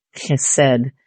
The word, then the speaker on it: chesed: Maybe you could spell it C-H and then hit that hard in the back of your throat, chesed.